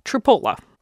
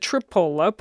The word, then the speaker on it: Tripoli: Because the way it's spelled, it looks like it would be Tripoli, doesn't it?